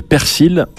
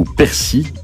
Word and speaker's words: persil: C'est le persil ou persil. – D'accord. – Certains prononcent pas le L à la fin.